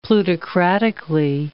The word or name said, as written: plutocratically